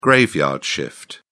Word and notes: graveyard shift: British English

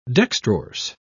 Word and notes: dextrorse: British English

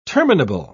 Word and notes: terminable: British English

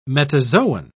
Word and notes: metazoan: British English

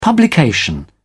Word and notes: publication: British English

